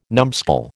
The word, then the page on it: numbskull: American English